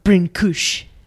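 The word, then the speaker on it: Brâncuși: That is Constantine Brancusi. – It is. I think it is pronounced Brincouche. – Oh, really? Mm-hmm.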